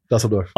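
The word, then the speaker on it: Düsseldorf: But you know, like the other places, like we were in Dusseldorf. How do you say it? – Yeah, you got it. Dusseldorf.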